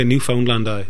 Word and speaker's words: Newfoundland: Well, you actually say all of the syllables. – Yeah, we're Scots, so anyway, Newfoundland.